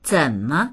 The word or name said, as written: zen3me